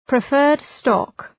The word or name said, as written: preferred stock